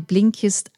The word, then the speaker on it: Blinkist: Ich wiederhole nochmal, wie Blinkist ausgesprochen wird. B-L-I-N-K-I-S-T. Blinkist, so schreibt ihr es auch.